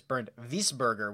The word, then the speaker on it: Bernd Wiesberger: I saw that they moved, Bernd Wiesberger, or actually I think it's pronounced, oh, here he is, Bernd Wiesberger would be the right way to say this.